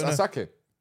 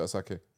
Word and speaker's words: Asake: Oh, so it's Asake. – Asake. – Okay, but you pronounce it Asake.